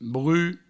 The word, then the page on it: bruch: brut